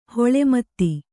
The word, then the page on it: ಹೊಳೆಮತ್ತಿ: hoḷe matti